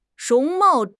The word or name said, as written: 容貌